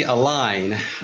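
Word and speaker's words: Aline: So it's actually Align. I'm actually Spanish. It's a French first name, but it's Align.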